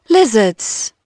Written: lizards

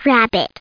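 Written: rabbit

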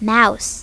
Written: mouse

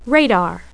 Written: radar

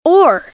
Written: oar

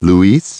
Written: Luis

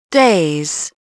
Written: DAYS

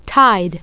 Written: tide

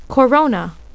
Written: corona

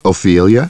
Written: Ophelia

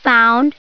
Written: found

